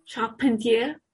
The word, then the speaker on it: Charpentier: Manuel Carpentier, no sé si se pronuncia así, Charpentier. – ¿Tú sos la que sabe francés aquí? – Ah, ¿es francesa? – Sí, no sé. – Manuel Charpentier.